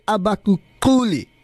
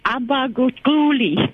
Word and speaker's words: Abaguquli: Listen, am I pronouncing this correctly when I said Abaguquli? – It's Abaguquli.